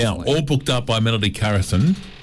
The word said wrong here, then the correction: Carlson: Is that how you say it? Carlson. – Yeah. – Because we've got an L not an I.